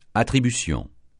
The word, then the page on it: attribution: francais